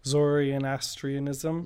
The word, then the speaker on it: Zoroastrianism: So yeah, Nowruz has been going around for about 3000 years, and it started with the Zoroastrian Zoroastrianism, if I'm saying it right. – Zoroastrianism. – Yes, thank you.